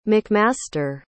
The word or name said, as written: mcmaster